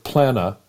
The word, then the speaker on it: Plannuh: So tell well, us how to, the correct pronunciation of Plannuh and, and, and why you guys picked the name.